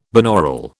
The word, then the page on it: binaural: British English